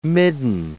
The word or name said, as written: midden